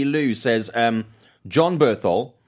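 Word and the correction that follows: Bernthal: Berthold? I always get his name. Berenthal? – Yeah, Berenthal.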